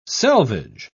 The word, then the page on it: selvedge: British English